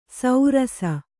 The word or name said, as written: ಸೌರಸ